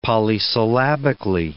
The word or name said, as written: polysyllabically